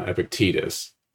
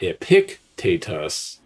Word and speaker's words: Epictetus: I don't, the accent is not on the antepenult. So the antepenult is the third to last syllable of a word. The penult is the second to last, and the last syllable is the ultima. So it's not epictetus, it's epictetus or epictetus.